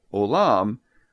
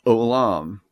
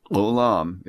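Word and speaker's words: olam (עולם): And so, since we can't represent that letter, we put a single quotation mark in front of the O, so that instead of, for example, saying olam, Hebrews would pronounce this with a very guttural sound and say olam, olam, if you can see the difference or hear the difference.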